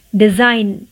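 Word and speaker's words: design: डिज़ाइन जी का sound नहीं आएगा जी का प्रोनाउंस नहीं आएगा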